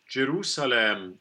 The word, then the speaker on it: Jerusalem: Jerusalem, traditionally pronounced in Messiah with E. – At the end. – And that's okay. – Yeah. – We do that. – And the S in this word in English is unvoiced.